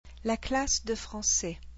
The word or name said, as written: la classe de français